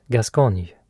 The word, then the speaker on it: Gascogne: Gascogne. Ett landskap i Frankrike som uttalas Gascogne.